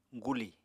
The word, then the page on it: guli: Apatani